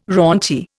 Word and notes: raunchy: Indian

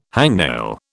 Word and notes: hangnail: British English

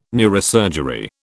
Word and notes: neurosurgery: British English

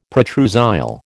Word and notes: protrusile: American English

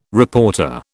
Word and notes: reporter: British English

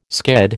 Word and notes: sked: American English

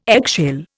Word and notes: eggshell: Indian

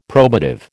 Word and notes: probative: American English

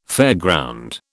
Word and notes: fairground: British English

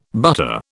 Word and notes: butter: British English

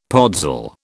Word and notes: podzol: British English

